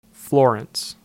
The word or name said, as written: Florence